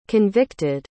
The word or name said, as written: convicted